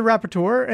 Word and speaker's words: rapporteur: Rapporteur. – Sure. Yeah. Sure. Just nail it. Just crush it and make me look horrible. Way to go. Thanks a lot, buddy. But you get the rapporteur and then you get the same guy.